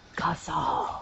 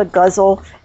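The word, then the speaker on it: ghazal: It looks like it should be guzzle, but it isn't. It's called a guzzle, and that's a close pronunciation.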